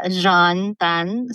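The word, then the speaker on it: Jeanne Tan: Okay, so people can just Google Jean Tan. So my name is spelled as J-E-A-N-N-E, and the last name is T-A-N.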